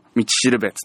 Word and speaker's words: 五稜星: 100 万ドルの道しるべつって、五稜星って書いて道しるべって読むんですけどね、これね、ほんと最後もう心臓がキュッてなりますからね、まだ見てない人はぜひ見てほしいなと思ってます。